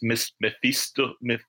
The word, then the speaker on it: Mephistopheles: It's Mephistopheles.